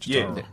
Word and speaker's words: Jotaro: Jotaro. I think that's how you say it. Jotaro. – Yeah. You're right.